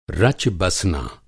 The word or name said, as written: रच-बसना